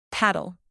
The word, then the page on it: paddle: verb